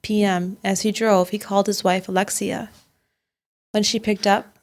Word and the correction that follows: Elixcia: That's Elixcia. You have an interesting accent that makes it sound like Alexia, but it's Elixcia.